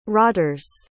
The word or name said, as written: rotters